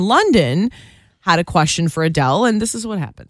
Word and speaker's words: Adele: That's not how you say her name. Adele. Adele? Adele? – Adele.